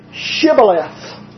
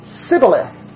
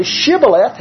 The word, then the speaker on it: Shibboleth: And so they asked them, say the word Shibboleth. Ephraimites couldn't pronounce the H in Shibboleth. So they said, Shibboleth, and they were killed then.